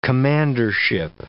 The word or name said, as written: commandership